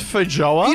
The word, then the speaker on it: feijoa: Are you sure it's pronounced feijoa? – You know what I tried to do? – Yeah, it is.